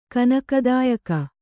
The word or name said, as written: ಕನಕದಾಯಕ